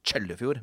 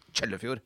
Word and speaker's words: Kjøllefjord: Og så Lebesby kommune, nesten så langt man kommer nord I landet, I Finnmark da, I Kjøllefjord, eller det som, hvis man skal være helt korrekt, uttales Kjøllefjord. Med, altså det staves med KJ, men det skal uttales med TJ. Kjøllefjord.